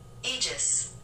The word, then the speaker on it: Aegis: Is it spelled like A-E-G-U-S? – Uh-huh. – Okay. – Well, how do you say that? – I thought it was Agus. – That sounds more right. – Can I Google that really quick? – Yeah. – Aegis. – Oh, Aegis.